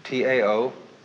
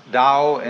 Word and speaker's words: Tao: T A O, pronounced Dao in the Mandarin dialect, Tao in the Shanghai dialect, To in the Cantonese dialect. Take your choice.